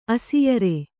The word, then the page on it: ಅಸಿಯರೆ: asiyare